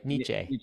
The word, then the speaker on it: Nietzsche: Sorry, Nietzsche.